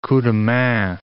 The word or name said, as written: coups de main